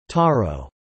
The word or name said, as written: Taro